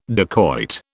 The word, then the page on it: dacoit: British English